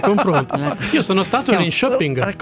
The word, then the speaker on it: Linköping: ci sta ascoltando da un luogo che ci scrive la pronuncia, è Linköping. Ping.